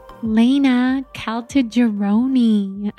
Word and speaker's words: Laina Caltagirone: All right, Laina Caltagirone.